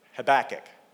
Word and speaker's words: Habakkuk: but Habakkuk? I said it right. Habakkuk. It's not Habakkuk. Habakkuk. I'm getting some nods, but I'm going with it. I'm pretty sure I'm right.